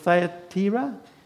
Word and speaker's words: Thyatira: It says there, one of those listening was a woman from the city of Thyatira. Did I say that correctly? Thyatira? Thank you.